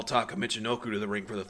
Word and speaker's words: Taka Michinoku: Taka Michinoku. Thank you, Sonny, for pronouncing that correct.